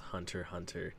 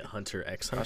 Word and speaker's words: Hunter x Hunter: No, to be fair, I have heard that it is actually pronounced Hunter Hunter. And I have also seen everything that it is for sure 100% pronounced Hunter Hunter. So I am sorry to the anime community when I have been calling it Hunter x Hunter.